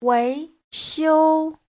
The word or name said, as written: wei2xiu1